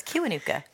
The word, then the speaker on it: Kiwanuka: That's Karen O with You're My Saint with Michael Kiwanuka featuring. – You said it wrong. – Did I? – You said Kiwanuka. – Come on, correct There's me. There's no R in there. It's Kiwanuka.